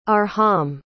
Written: arham

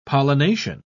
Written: pollination

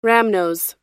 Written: rhamnose

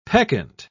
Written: peccant

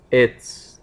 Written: it's

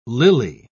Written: lily

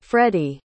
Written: freddi

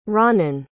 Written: Ranen

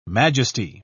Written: majesty

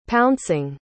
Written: pouncing

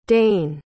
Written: dain

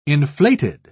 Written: inflated

